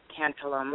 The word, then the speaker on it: tantalum: I'm not sure if I'm pronouncing that right, tantalum.